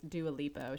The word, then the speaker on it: Dua Lipa: I asked Kayla. It was Dua Lipa.